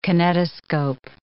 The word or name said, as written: kinetoscope